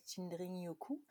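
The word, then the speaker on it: Shinrin-yoku: Euh, c'est assez bien prononcé. C'est sûr qu'à la japonaise, il y a un petit dring-dring, Shinrin Yuku.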